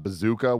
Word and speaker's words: BZKA: We had Bazooka, which is the best name I've learned last night because it's spelled BZKA.